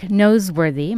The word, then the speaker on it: Noseworthy: Jack Noseworthy.